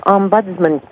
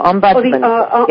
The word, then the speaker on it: Ombudsman: It's the Nursing Home Residents Bill of Rights and also the Long-Term Care Ombudsman Program, and I may not have pronounced that exactly correctly.